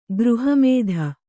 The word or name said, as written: ಗೃಹಮೇಧಿ